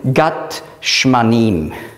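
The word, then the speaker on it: Gat Shmanim (גת שמנים): Auf Hebräisch würde man schreiben Gat Shmanim. Auf Griechisch gibt es kein Sch und darum nimmt man das S.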